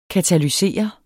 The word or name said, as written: katalysere